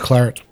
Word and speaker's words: claret: This innovative style of wine, known as the New French Claret, became hugely popular on Claret. – The... Claret? – It's an English word. French, yep. Claret.